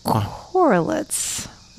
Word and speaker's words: Korelitz: I have a book called The Plot by Jean Hanf Korelitz. Is how I'm going to pronounce that last name. K-O-R-E-L-I-T-Z. – Okay. – Korelitz. – Korelitz, sounds right.